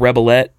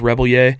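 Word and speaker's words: Rebillet: So it's kind of spelled Rebelette, but it's pronounced Rebelier.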